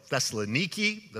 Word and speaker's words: Thessaloniki: If you go to a tour in the footsteps of Paul, as I have been on, and you go to Philippi, and then they call it Thessaloniki. That's the way you pronounce it.